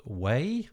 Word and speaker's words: Wei: One of them is called Wabi, W-A-B-I, and one of them is called Wei. I think I've pronounced that correctly. – Yeah, that's right.